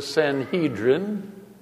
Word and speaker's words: Sanhedrin: That would be the Sanhedrin, or some might pronounce it the Sanhedrin,